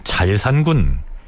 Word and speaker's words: 자을산군: 성종의 왕자 시절의 이름이 실록에는 자산군, 자을산군 이렇게 올라 있기도 한데요. 훈민정음의 발음상 새 을자가 리을 발음 역할을 하는 것으로 파악을 하면 잘산군 이렇게 칭해야 한다는 견해가 지배적입니다.